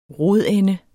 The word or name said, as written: rodende